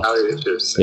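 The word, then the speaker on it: Kali Uchis: It was Callie Uchis, or if I'm saying the last name wrong. – Callie Uchis.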